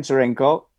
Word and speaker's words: Tscherenkow: It's my first name and my last name, so Stan Cherenko, with T-S-C-H-E-R-E-N-K-O-W, and I prefer, I like what Arnold Schwarzenegger, by the way, said.